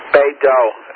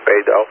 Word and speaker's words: Beidou: BDS, Beidou, I think is the way they pronounce it, Beidou.